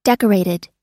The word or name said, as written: decorated